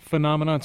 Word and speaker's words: phenomenon: I'm pretty sure, Todd, phenomenon starts with a P. – Youp. – But if you want to do it phonetically, that's fine, too. – And phonetically starts with a P. – By God, it does. – Yeah, it does. But it's pronounced with an F.